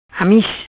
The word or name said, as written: Amiz